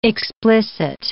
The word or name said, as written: explicit